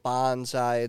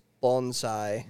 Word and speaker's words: bonsai: Well, also to be fair, people pronounce bonsai wrong. It's not bonsai. It's bonsai.